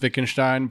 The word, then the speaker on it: Wittgenstein: It is pronounced Wittgenstein, but yet he will choose to continue to call it him Wittgenstein.